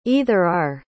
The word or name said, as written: either-or